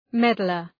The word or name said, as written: meddler